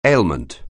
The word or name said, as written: ailment